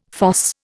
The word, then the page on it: fosse: Indian